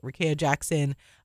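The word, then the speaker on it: Rickea Jackson: Rekaya Jackson? Is it Rekaya Jackson? – Yes. – Okay, Rekaya Jackson.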